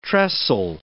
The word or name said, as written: trestle